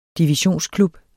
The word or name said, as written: divisionsklub